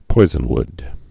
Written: poisonwoods

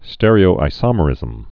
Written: stereoisomerism's

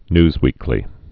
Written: newsweekly

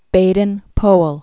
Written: Baden-Powell

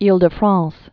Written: Ile-de-France

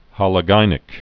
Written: hologynic

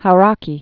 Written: Hauraki Gulf

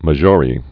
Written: Maggiore, Lake

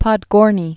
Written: Podgorny

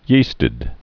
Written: yeasted

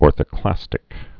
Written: orthoclastic

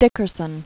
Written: Dickerson, Eric Demetric

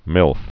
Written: MILF

